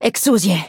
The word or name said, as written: Exusiai